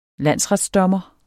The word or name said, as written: landsretsdommer